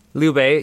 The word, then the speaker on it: Liu Bei: But the advisor to Liu Bei? – Yeah, yeah. – Did I pronounce it correct? – Liu Bei, yeah, you got that word right.